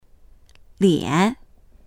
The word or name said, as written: lian3